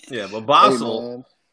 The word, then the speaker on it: Basel: I think the correct term for it is Basel. People say basil. I think basil is okay.